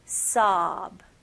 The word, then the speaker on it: sob: 주로 여기 오, 중간에 오 있는 단어들은 많은 한국 사람들은 이 입술을 사용해서 이렇게 오, sob 이런 식으로 많이 발음하시더라고요. 근데 여기서는 입술은 사용하지 않고 그냥 턱만 벌리는 느낌으로 보세요. sob.